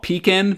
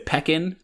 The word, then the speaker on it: Pekin: In that very same episode, like minutes after we talked about Huga, we mispronounced the name of a town in Illinois called Pekin, but we said Pekin. – But to my credit, it was spelled P-E-K-I-N. Like, wouldn't Pekin have two E's, like P?